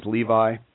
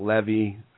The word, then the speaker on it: Levi: And my last name is Levi. Common spelling, L-E-V-I, right? Growing up, I got Levy.